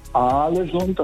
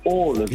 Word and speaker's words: Ålesund: Also das heißt nicht Alesund, das heißt Ålesund.